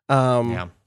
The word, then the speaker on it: Klaasje: It's K-L-A-A-S-J-E, which I don't know how to pronounce. Klaasje, says the internet.